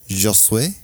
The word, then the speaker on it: Josué: J-O-S-U-E, accent. – Aigu? – Aigu. Josué? – Josué.